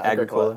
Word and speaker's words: Agricola: Is it Agricola? Is that how you pronounce it? – Agricola, I would say.